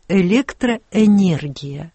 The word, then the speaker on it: электроэнергия: При написании стоит буква «е» после согласного. А как произносить как «э» или как «е» – неизвестно. Например, меня в свое время учили, хоть это было очень трудно, язык сломаешь, как говорится, но надо говорить «электроэнергия».